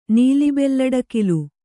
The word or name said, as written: ನೀಲಿಬೆಲ್ಲಡಕಿಲು